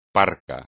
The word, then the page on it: parco: espagnol